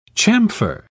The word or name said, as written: chamfer